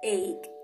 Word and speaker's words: ache: Sí, yo lo sé, dice H, pero no lo pronunciamos como H. Suena como ache, ache. Como si lo terminaras con una K, ¿ok?